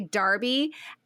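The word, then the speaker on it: Derby: And so she tells her that the day after they left, just a quick note, my friend in the UK, Claire, taught me the correct pronunciation of the town in which Darcy lives. So it's like Darby, like Darcy. So you say Darby,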